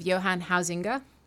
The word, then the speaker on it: Huizinga: which I think I'm pronouncing all right because I I once listened to a YouTube pronunciation of his name. um, it's H U I Z I N G A.